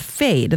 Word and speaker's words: Phade: So these are new straws, eco-friendly and easy to use, and they're called Fade, and that's spelled P-H-A-D-E.